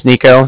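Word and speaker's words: Niko: Again, my name is Nico. That's N-I-K-O.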